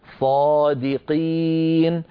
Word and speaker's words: صادقين: صادقين، دي لابد من ترقيق الدال، قي أن ننطق قاف صافية ليس فيها شيء من الكاف، صادقين.